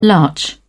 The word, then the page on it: larch: British English